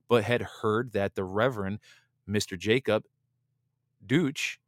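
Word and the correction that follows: Duché: pronounced Duche, excuse me, yeah, Duche,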